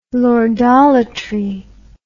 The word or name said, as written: lordolatry